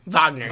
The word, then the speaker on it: Wagner: If they could just stop, how do you pronounce it, Wagner? – Wagner. With a W.